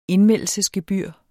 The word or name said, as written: indmeldelsesgebyr